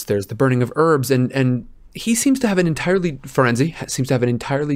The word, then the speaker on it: Firenze: they have their first lesson with Firenze, and we are going Firenze, because that, we have confirmation from a real-life Italian, that that is indeed the pronunciation.